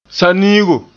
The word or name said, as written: saniigo